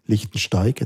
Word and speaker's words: Lichtensteig: Und du hast es dargelegt, wie das jetzt vielleicht im, im Rahmen von Lichtensteig, jetzt habe ich es richtig gesagt, äh, funktioniert, Lichtenstein mit G.